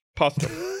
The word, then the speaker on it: Postum: And you pronounced it wrong, and well, now we all think it's called Potsum.